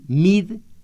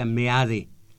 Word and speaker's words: Meade: A ustedes los están haciendo que digan mid y en castellano se pronuncia meade.